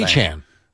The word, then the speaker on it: Etienne: Achan.